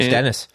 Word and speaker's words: Denis: Denis Thibault. French Denis.